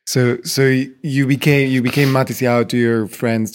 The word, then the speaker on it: Matisyahu: So Matityahu. But the Ashkenazi pronunciation is Matisyahu.